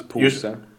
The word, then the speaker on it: pose: Because pose. – Pus, okay, so the O, when it's written O, it's pronounced as a U.